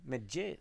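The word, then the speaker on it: Medjedović: Vi såg nämligen Hamad Nedjeljovic. – Medjedovic. – Vad fan. – Svårt uttal. Medjedovic, va? Eller?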